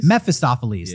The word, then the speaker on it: Mephistopheles: Mephistopheles? – Mephistopheles.